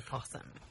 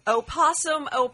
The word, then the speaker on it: possum: I just say possum. – Awesome. – I never just say possum. The O is silent. That's what's so confusing about it.